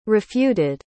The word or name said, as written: refuted